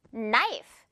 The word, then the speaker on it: knife: K로 시작하면서 바로 N부터 나와서 K는 그냥 없는 거나 다름이 없어요. Knife. 이 K를 아예 그냥 없다고 생각하고 항상 발음하면 돼요.